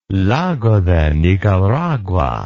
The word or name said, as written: Lago de Nicaragua